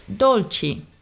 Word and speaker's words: dolci: And you can also hear this in the word dolci, which you may see on an Italian menu. It means desserts. So the last syllable C-I is pronounced chi.